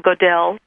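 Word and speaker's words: Gödel: Let's talk about a man, Goodell. And I may be mispronouncing his name, so if I am, please correct me. – I think it's close. Goodell. Kurt Goodell.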